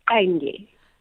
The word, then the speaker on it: Qenge: It's Qenge.